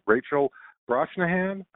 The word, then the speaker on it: Rachel Brosnahan: The lead actress, and I hope I'm pronouncing her name right, Rachel Brosnahan, I think that's how you pronounce it.